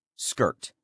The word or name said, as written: skirt